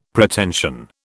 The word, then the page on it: Pretension: British English